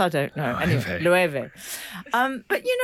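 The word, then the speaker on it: Loewe: Frank said. Oh, low. And I said, no, it's Lueve.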